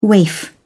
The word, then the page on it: waif: British English